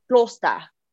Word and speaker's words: Gloucester: I don't know if I'm pronouncing that right. G-L-O-U-C-E-S-T-E-R. – Gloucester? – Gloucester. – Gloucester. – Gloucester. – Try it with a dialect. You got to try it with a dialect. – Gloucester. – Gloucester. – Gloucester. – That's it. I think you got it.